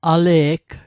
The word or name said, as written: ale'g